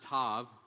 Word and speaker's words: Tav: it looks like the letter Tau, T-A-U, it's pronounced Tav, and oftentimes spelled that way as well, T-A-V, so those are interchangeable.